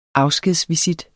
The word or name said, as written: afskedsvisit